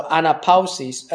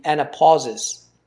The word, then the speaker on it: anapausis: So anapausis, and it's spelled A-N-A-P-A-U-S-I-S. So you could, if you wanted to anglicize the pronunciation, it would be anapausis.